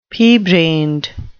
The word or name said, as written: pea-brained